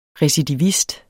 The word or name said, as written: recidivist